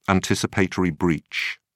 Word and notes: anticipatory breach: British English

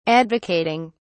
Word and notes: advocating: British English

